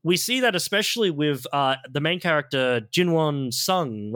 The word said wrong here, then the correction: Jinwoo Sung: right? That's how you say it? – Jinwoo Sung, yeah.